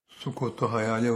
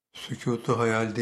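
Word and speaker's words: sukut-u hayal: Sükutu hayal değil, hayal susması değil. Sukutu hayal, hayal düşmesi konunun aslı. Her konuyu da kelime yapısı itibariyle yanlış telaffuz ediyoruz, yanlış değerlendiriyoruz. Bu vesileyle sukutu hayal sözünü kullananlar var hala ama sükutu hayal diye kullanıyorlar. Yani hayal susması. Sukut düşme demektir.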